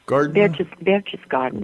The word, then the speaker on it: Berchtesgaden: It's pronounced Berchtesgaden? – Berchtesgaden. – Berchtesgaden.